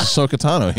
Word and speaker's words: Ahsoka Tano: I said it wrong again, didn't I? What is it? – No, you actually got it right this time. It's Ahsoka Tano. Here, you're right. – I said Tawa.